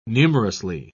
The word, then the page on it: numerously: British English